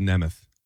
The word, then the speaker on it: Nemeth: It's pronounced Nemeth. Nick Nemeth.